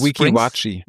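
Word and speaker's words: Weeki Wachee: Meerjungfrauenshow in, wie wird es ausgesprochen? Watchy Springs? – Wiki-Watchi. – Wiki-Watchi Springs, okay.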